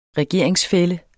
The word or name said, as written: regeringsfælle